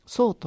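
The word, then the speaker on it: 倉: それから今月 6 月は、南谷、隅田パークスタジオ、隅田区にあるねパークスタジオ、新しくなったパークスタジオ、そう、蔵と書いてそうというところで、劇団桟敷童子の、舞台を見てまいりました。これが劇団桟敷童子っていうのはもう、そうですね、結成されてからやっぱり 20 年ぐらいになるんですかね。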